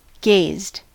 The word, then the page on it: gazed: American English